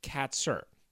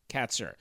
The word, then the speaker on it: Kaczor: First of all, his last name is spelled K-A-C-Z-O-R, but it's pronounced Katzer. Katzer. Occasionally, I'm going, just be honest, as I've already done, call him Katzer. It's Katzer. Katzer. K-A-T-S-I-R is how you would basically pronounce it phonetically, or K-A-T hyphen S-I-R.